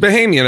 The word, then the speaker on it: Bahamian: Bahamian, I believe. – Bahamian. Right, yeah. So are his friends, the two other guys on the boat, local Bahamians? – I think they are supposed to be Bahamian, but he is speaking with a Jamaican accent.